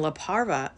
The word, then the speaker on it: La Parva: But in La Parva, a ski spot in the Chilean Andes, am I saying that right? Yeah.